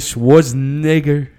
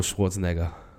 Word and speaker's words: Schwarzenegger: One with Schwarzenegger. How do you... I actually don't know how to pronounce his last name. – Schwarzenegger. – Arnold Schwarzenegger. – Schwarzenegger. – Okay, cool.